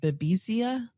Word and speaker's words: Babesia: but I don't know how you pronounce it. Babesia. – Yes.